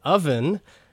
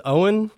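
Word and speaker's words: Ovven: Is the product of Nashville's Owen Burton, so it's O-V-V-E-N, kind of looks like a W. I think I pronounced it as Owen last week. I have since learned he pronounces it Oven, so that's pretty fun.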